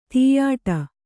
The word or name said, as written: ತುಯ್ಯಾಟ